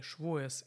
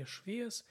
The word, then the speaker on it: Шавуот: Шавуот от иврита Шавуот в ашкенадском произношении Швоес или Швиэс – это Пятидесятница.